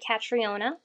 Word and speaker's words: Catriona: It's spelled like Catriona, so C A T R I O N A. So the O is decorative.